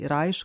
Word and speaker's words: Reich: Idući stopama Lamonta Younga i Terija Rileya, kompozitor Steve Reich ili Reich, kako njegovo ime izgovaraju u Americi, opšte je poznat kao treći najznačajniji pionir minimalizma.